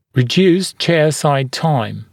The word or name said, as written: reduced chairside time